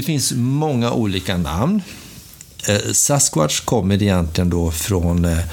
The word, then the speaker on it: Sasquatch: Nu säger du Bigfoot här, men egentligen vill du säga det Sasquatch. Ja. Uttalar jag det på rätt sätt? Ja, det gör du nog.